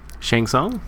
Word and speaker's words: Shang Tsung: Shang Tsung? – Shang Tsung, yeah.